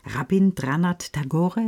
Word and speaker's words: Rabindranath Tagore: Max Frisch, Hermann Hesse und Rabindranath Tagore, ist das richtig ausgesprochen? – Ja, genau.